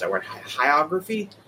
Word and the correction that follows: hagiography: of Vince before we even know this terrible thing that is potentially coming down the road. People were talking about hagiography.